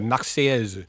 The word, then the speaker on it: Marseillaise: It's the Marseillaise. – It is. It is. In French, this is a Marseillaise.